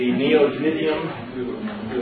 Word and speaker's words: neodymium: The neodymium, that's the way it's pronounced, magnets, but little tiny, super rare earth, very powerful magnets.